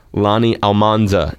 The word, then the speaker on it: Almanza: Lonnie Almanza is the senior market manager for the American Cancer Society. Am I pronouncing your last name correctly? – You are.